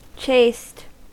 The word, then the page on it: chaste: American English